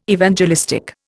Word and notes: Evangelistic: Indian

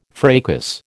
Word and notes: fracas: American English

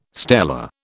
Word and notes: stela: British English

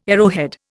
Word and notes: arrowhead: Indian

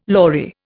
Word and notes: lory: Indian

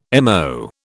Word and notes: MO: British English

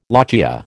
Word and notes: lochia: American English

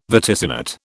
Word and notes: vaticinate: British English

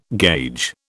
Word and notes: gauge: British English

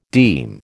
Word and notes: deme: American English

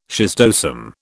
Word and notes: schistosome: British English

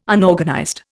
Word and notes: Unorganized: Indian

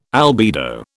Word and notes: albedo: British English

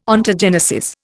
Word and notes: Ontogenesis: Indian

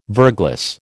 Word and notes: verglas: American English

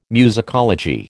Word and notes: musicology: American English

